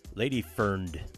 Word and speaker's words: Ladyfrnd: What you just heard there from earlier this year was Lady Friend, except you lose some of the vowels at the end, so you spell it L-A-D-Y-F-R-N-D. Lady Ferned.